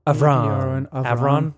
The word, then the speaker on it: Avron: I believe I'm saying that right. A-V-R-O-N. Avron. – Yeah, Avron, yeah.